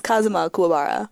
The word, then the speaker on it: Kazuma Kuwabara: Naoya Gomoto as Kazuma Kuwabara.